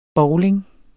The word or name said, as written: bowling